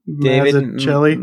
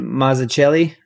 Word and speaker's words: Mazzucchelli: David Mazzucchelli. Mazzucchelli.